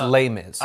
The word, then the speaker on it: Les Mis: You don't have to pronounce the S. It's Les Mis.